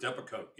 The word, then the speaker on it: Depakote: against the manufacturer of Depakote, is that how you pronounce it? Depakote. – Depakote, yes.